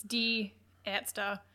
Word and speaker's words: Die Ärzte: My pronunciation is not great, but it's Die Ärzte. It's probably as close as... – Die Ärzte, I would have thought. – A with the umlauts, it means it's A-E, so air.